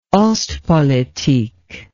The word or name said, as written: ostpolitik